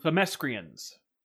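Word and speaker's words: Themyscira: And My Little Pony, I think that the Themescrians and the Equestrians should get together. Themescrians. Jim Lee pronounces it Themescria.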